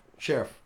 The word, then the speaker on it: Scherff: Sheriff? – Sheriff.